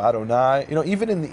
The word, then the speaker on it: Adonai: and the rabbis told them that word is pronounced as Adonai.